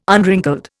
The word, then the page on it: unwrinkled: Indian